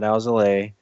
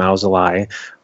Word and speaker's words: Alzolay: Yeah, and then my take on Adbert Alzolay, not Alzolay. There's been a big talk about how that's how you actually pronounce his name, Alzolay.